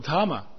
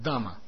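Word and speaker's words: дама (धाम): Поэтому санскритское слово дхама, да, знаете, Вриндавана дхама, Матхура дхама, вот. На самом деле оно должно произноситься как дама. Дхам, дам, дом.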